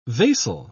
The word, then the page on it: vasal: British English